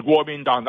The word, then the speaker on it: Kuomintang: Is KMT, is that, that's Kuomintang, or am I saying that wrong? – That's right. That's Kuomintang. That's kind of a phonetic translation of the Chinese Nationalist Party.